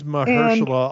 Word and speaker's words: Mahershala: It is Mahershala Ali.